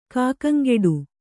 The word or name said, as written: ಕಾಕಂಗೆಡು